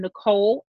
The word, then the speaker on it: Nicole: And on Facebook, I am Nicole Alexander. That's N-I-C-O-L-E. I know some people spell it with an H, but it's N-I-C-O-L-E Alexander, Dare to Be Fit LLC.